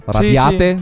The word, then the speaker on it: Radiate: Ci sono anche i Goldfrapp con una canzone, We Radiate. Secondo me si legge così. Radiate. – Sì, sì. Radiate.